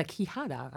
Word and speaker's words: quijada: And it's called a quijada, I believe, if I'm pronouncing that correctly, the donkey jawbone that she plays.